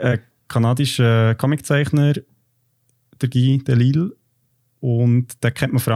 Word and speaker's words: Delisle: Also man schreibt das Delisle, aber ich habe mich informiert, das spricht man Delisle aus, offenbar.